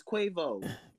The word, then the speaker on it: Quavo: Yes, it's Quavo.